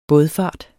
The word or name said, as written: bådfart